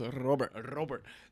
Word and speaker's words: Robert: And so the way he pronounced it was Robert.